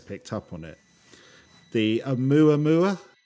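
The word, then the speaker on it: Oumuamua (ʻOumuamua): Oumuamua. – Oumuamua.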